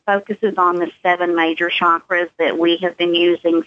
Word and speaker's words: chakras: So why don't you tell people first a little bit about what the chakras are, or as some people will pronounce it, chakras.